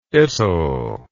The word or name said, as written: itll